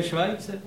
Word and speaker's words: Schweitzer: Myslíte náhodou Albert Schweitzer? – No já myslím toho, jak v Africe tu nemocnici jako nechal postavit. – No to se vyslovuje Švajce.